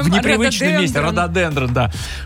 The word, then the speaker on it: рододендрон: Когда была на севере Таиланда, поднималась на самую высокую точку, и там оказался целый лес из рододендронов высотой, наверное, 5 метров. – Сейчас тебе напишут, что все-таки правильное ударение рододендрон.